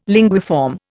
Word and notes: linguiform: Indian

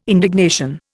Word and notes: indignation: Indian

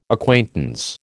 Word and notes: acquaintance: American English

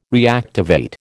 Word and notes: reactivate: American English

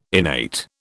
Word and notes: innate: British English

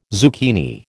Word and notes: zucchini: American English

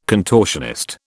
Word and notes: contortionist: British English

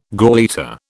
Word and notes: gauleiter: British English

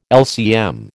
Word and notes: LCM: American English

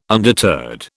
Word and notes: undeterred: British English

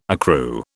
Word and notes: accrue: British English

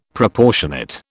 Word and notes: Proportionate: British English

